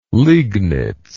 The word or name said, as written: liegnitz